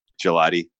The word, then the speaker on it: Gelati: Vince, and people can find you on Twitter at Gelati, LOL. – That's correct. – Am I pronouncing that correctly? – That is correct, yes. – Okay, cool. – Like the ice cream? – Just like the frozen dessert, yes.